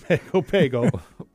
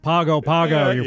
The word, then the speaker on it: Pago Pago: I'm saying it wrong. Please let us know. Email us. – Right. I'm sure we've got many listeners down there. I'm sure they're, the board's lighting up now, isn't it? – Yeah, we'll probably get a call. It's pronounced Pago Pago, fools.